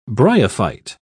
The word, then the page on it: bryophyte: British English